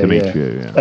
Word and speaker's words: Demetriou: Yeah, Demetriou, yeah.